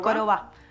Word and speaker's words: Koroba: ¿Se dice así? ¿Koroba? – Koroba.